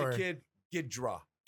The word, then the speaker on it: Ghidorah: And, so wait a minute, how do you pronounce it? Ghidorah? – Ghidorah, King Ghidorah.